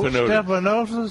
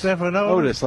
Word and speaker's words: Stephanotis: Stephanotis. – Stephanotis. – Otis. – Stephanotis. Like Otis from the Andy Griffith Show.